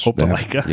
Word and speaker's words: Opelika: Yeah, that's Opelika.